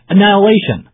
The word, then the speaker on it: annihilation: Eskin 这样说道：“Today we take not another step towards annihilation. 我们今天这么做不是为了歼灭敌人，but the first step on the path to peace，而是迈向和平的第一步。 Annihilation, A-N-N-I-H-I-L-A-T-I-O-N, annihilation，歼灭、灭绝的意思。” 在这里，H 是不发音的，有点像那个展览那个词 exhibition。